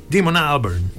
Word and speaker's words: Damon Albarn: Damon Albarn, così si pronuncia, l'ho sentito nominare una volta a radio inglese, non capivo, dico, ma chi è? Ah, Damon Albarn, in realtà si direbbe Damon Albarn.